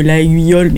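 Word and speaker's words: Laguiole: Oui, alors ça s'écrit Laïole, mais c'est Laïole. Oui, comme les couteaux.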